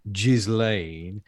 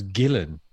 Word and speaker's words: Ghislaine: It's not Ghislaine. He insisted it was Gillen.